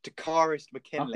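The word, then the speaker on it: Takkarist McKinley: So they've gone out and they got, pardon the pronunciation of this, Takaris McKinley.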